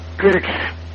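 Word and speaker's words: QIRX: Oké, de letters van het programma, dat is de Q, de Quebec dus, de India, de Romeo en de XC, Quirks. Heel snel uitgesproken Quirks en...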